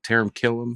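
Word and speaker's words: Taran Killam: And actually, Taron, how you say it? Taron Killam? – Yeah.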